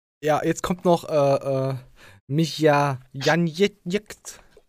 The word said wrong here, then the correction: Janjetz: Ähm, wie wird er überhaupt richtig ausgesprochen? Michael? Janjetz. Janjetz. Äh, Tschecher?